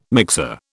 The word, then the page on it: mixer: British English